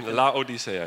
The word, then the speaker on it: Laodicea: So Laodicea, you say máte tam.